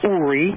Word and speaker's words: Uri: Well, because I got used to people calling me Yuri. Yuri is a Russian name, and it's spelled Y-U-R-I. – Right. – My name is ancient Hebrew. It's Uri, mm-hmm.